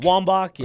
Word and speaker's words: Wambach: Wambach, yeah.